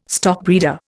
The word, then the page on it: stockbreeder: Indian